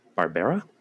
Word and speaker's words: Barbera: Michael Pataki played Captain Barbera? – Yeah, they say it like Hanna-Barbera.